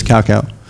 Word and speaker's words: cacao: I have 100% cacao. I'm not saying it wrong. It is cacao.